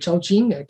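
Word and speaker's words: Zhaoqing: Euh, euh, je vais visiter une ville qui s'appelle Chaoqing, qui est à deux heures de route de, de Canton à l'époque, de Guangzhou, et qui est une ville magnifique.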